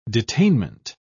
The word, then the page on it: detainment: British English